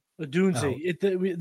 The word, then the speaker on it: Odunze: But at the combine, Roma Dunze feels like, I hope I'm saying it right, Odunze or Dunze. I'm going to figure that Odunze. The pronunciations are in the interview schedule.